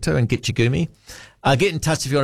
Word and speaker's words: Gitche Gumee: It's from the Ojibwe. Pronounced Gitche Gumee, meaning great sea.